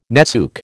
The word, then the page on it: netsuke: American English